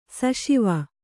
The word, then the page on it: ಸಿಸವೆ: sisave